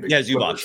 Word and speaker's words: Zubac: How do you pronounce his name? – Zubac. Yeah, exactly.